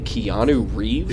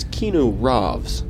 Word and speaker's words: Keanu Reeves: Hold on, did you say Keanu Reeves? – He sounds very similar. – No, it's Keanu Reeves. – Oh. – You're mispronouncing that. Keanu Reeves.